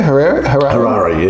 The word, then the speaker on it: Harare: Harare, yeah.